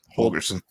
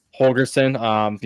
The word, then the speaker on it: Holgorsen: And then Dana, I always forget how to say his last name. – Holgorsen. – Holgorsen.